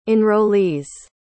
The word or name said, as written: enrollees